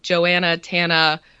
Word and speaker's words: Joanna Tana: And also, so if you say them together, which my parents, my mother should have, Joanna Tana rhymes with banana.